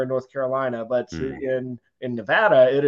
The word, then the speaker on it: Nevada: I was instructed by those who live there that it's pronounced Nevada, and I'm going to try to follow that.